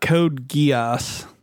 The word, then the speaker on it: Code Geass: About Code Geass.